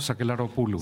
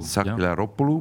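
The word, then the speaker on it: Sakellaropoulou: Also seit zwei Jahren ist ja eine Frau Staatspräsidentin, Katharina Sack Sakelaropoulou, habe ich mhm. das richtig gesagt? Sakelaropoulou. Sakelaropoulou, ja.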